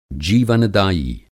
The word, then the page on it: जीवन-दायी: Hindi